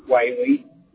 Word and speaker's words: Whaley: Whaley. – Nice. – W-H-A-L-E-Y.